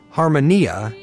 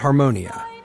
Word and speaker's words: Harmonia: Your show is called Harmonia, not Harmonia. Why is that? – Yes. Well, Harmonia is actually the Greek pronunciation